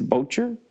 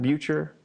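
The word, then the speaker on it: Boettger: Ike Bodger? – I think it's Bocher. – Bocher, not Bucher, but Bocher. – The mustard kind of, but with an R.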